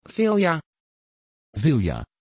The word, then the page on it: vilja: Dutch